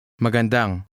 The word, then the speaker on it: Magandang: Note the stress on the last syllable. Listen and repeat again. – Magandang.